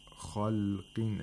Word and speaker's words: خَلْقٍ: And then we have خَلْقٍ. Again, where the خَ has تَفْخِيم and the قَاف has تَفْخِيم at the lowest level because of the تَنْوِين الْكَسْر. خَلْقٍ عَلِيمٍ.